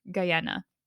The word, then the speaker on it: Guyana: You said people don't really know the roots of Guyana.